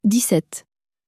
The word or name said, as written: dix sept